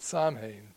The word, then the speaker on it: Samhain: I'm glad that you put on this little insert the word Samhain, because it doesn't look like it's pronounced that way. It's spelled S-A-M-H-A-I-N. And for many years, I mispronounced it Samhain, but it is actually in Celtic pronounced Samhain.